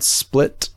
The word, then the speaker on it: SPL-T: It is called Split. It's spelled SPL-T.